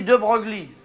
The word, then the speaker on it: De Broglie: Ça s'écrit De Broglie. C'est des noms de faucheton, ça s'écrit pas comme ça se prononce.